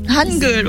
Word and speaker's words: Hangul: Hangul, voilà. Hangul, bon, H, on le prononce bien en français, en coréen, pardon, en fait.